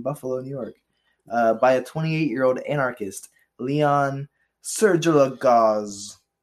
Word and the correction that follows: Czolgosz: It's Leon Czolgosz.